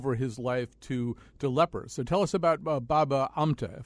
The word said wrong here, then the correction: Baba Amte: if I'm saying it correctly. – Yeah, Baba Amte, I think what you're thinking of, why he seems unlikely, is that he was born into a very aristocratic family.